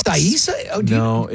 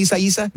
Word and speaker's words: Isaias: Oh, you said it wrong. – Isaias. That's what the pronouncer says. Isaias. – It's like Isaiah, but instead of Isaiah, it's Isaias.